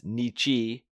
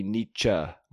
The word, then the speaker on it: Nietzsche: I refer to him every single time as Nietzsche, which is not the correct pronunciation. It should be Nietzsche, more like cha-cha-chop.